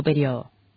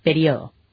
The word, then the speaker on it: periodo: Podés escribirlo con tilde en la I o sin tilde y pronunciar periodo. No puedes... Si vos vas a escribirlo con tilde, tienes que decir periodo. – Claro. – Pero si lo vas a escribir sin tilde, decís periodo. Cualquiera de las dos está bien.